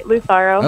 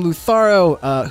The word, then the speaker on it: Lutharo: and it's Lutharo regular, or is there some specialty because of the umlaut on there? – I would say nope, there's no special All right. – way of saying it. You're saying it right, Lutharo.